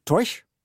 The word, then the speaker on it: Taoiseach: Och titeln för den irländska regeringschefen är Taoiseach, men uttalas säkert Toish, och betyder ungefär hövding.